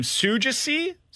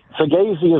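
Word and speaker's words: Saggese: It's Thomas, and I'm going to try to pronounce his last name, Sugesi. – Sugesi. Sugesi is, I believe, how it's pronounced. Now, I say this, I've asked people, but at the same time, I am terrible about pronunciation. But Sugesi.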